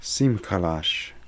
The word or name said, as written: Simkalash